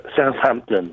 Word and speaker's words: Southampton: I don't know if you pronounce the H if you're talking about England. Southampton, English, England. Do you say Hampton or Ampton? – Southampton.